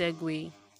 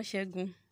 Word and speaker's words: segue: But the point is, it is actually pronounced segue. So no, it is not segue. It is not shegun. It is not shege. It is segue.